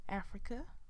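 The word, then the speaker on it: Afrika: My name is Africa with a K. That's A-F-R-I-K-A.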